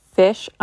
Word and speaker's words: Ghoti: So it really does make the F sound, the I sound, and the SH sound. So even though this is pronounced fish, I'm just going to say goatee because that's what it looks like, and then hopefully that will be a little less confusing.